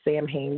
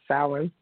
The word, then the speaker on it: Samhain: Some people pronounce it Samhain, but it's Samhain and um,